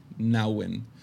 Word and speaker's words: Nouwen: es In the Name of Jesus o En el Nombre de Jesús de Henry Nouwen. Henry Nouwen. Para los que solo están escuchando, su apellido se escribe N-O-W. No, perdón, e N-O-U-W-E-N. Nouwen.